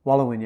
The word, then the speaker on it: Waluwin: And on that, you created, hopefully I pronounced this correctly, Wallowin? – Wallowin, yeah.